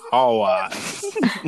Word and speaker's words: hours: No, no, no, te estaba diciendo que se pronuncia hours. Hours.